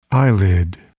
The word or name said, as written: eyelid